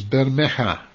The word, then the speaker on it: Bermeja: And let's start with this this disappearing island, which I think, if I got the pronunciation right, is Bermeja. – That is spot on pronunciation.